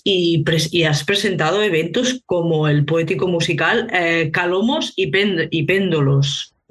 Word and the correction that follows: Cálamos: Cálamos, perdón, Cálamos, el acento.